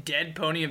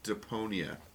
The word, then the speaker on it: Deponia: Did you say Deadponia of Doomsday? – Deponia. – Oh, they said Deadponia.